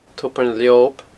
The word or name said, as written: tobar na leob